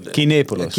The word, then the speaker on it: Kinepolis: Kinepolis. – Ja, Kinepolis.